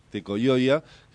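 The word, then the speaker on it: Tekojoja: Es presidente del Movimiento Popular Tecoyoya. Siempre me equivoco cómo sí. Se dice, pues se escribe con J, pero se lee Tecoyoya, que significa igualdad.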